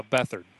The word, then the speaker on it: Beathard: Was it B- Beatharts or uh, Beathard? – Beathard. Yeah, his injury really kind of changed the, the dynamic of what what what happened during that game, didn't it? – Yeah, and I mean, yeah, that'll that'll happen.